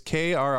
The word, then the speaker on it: Krsantan: It's Krsantan. Krsantan, I think is how you say it. – Krsantan. – There you go. – Yeah, you got to roll your R's to make it sound proper.